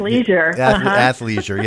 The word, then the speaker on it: athleisure: athletic leisure, you know what I'm saying, from that standpoint. – Athleisure, yeah, that's it, yeah, the ick is out of there. Just take the ick right out of that and then make things, yeah, athleisure, there we are.